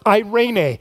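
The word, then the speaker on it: eirene (εἰρήνη): In the New Testament, it's written in Greek, and the Greek word for peace in the New Testament is eirene. Now, it looks different than that, but that's actually how you pronounce it, eirene.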